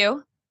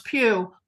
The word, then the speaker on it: Pugh: or I can never pronounce her name. – Florence properly. Pugh. – Florence Pugh, who plays one of his lovers.